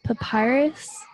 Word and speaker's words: Papyrus: Papyrus? – Oh, yeah.